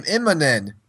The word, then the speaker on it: Immonen: But no, so Cassaday is leaving, and Immonen, see, James, is how you say the name, Immonen.